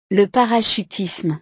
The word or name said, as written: le parachutisme